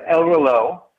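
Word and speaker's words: El Reloj: So when I pronounce it El Reloge, I'm pronouncing it wrong. – Well, I think you can pronounce it different ways. We pronounce it El Reloge, which means clock or watch in Spanish, which is the nickname of our historic cigar factory.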